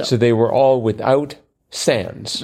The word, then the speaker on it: sans: And then, and the answer to that was, again, S-A-N-S. – So. – Or sans, as we pronounce it in this country.